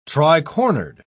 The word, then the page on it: tricornered: British English